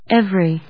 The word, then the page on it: every: ev・ery; évri